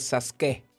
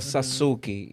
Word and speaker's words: Sasuke: pero yo no pronuncio, yo no digo Sasuke, yo digo Sasuki. Y aunque hay fanáticos de Naruto que siempre me corrigen, yo le digo, yo no tengo nada que ver con Naruto, o sea, yo soy otro.